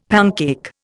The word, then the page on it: pancake: Indian